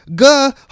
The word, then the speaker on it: GIF: It's gonna happen every time until Cody gets it right because it's graphic image format, GIF, GUH, hard G.